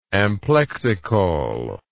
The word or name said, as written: amplexicaul